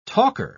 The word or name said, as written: talker